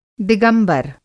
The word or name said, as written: दिगंबर